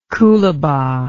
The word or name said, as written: coolabah